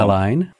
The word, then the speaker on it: Al Ain: Al Ain.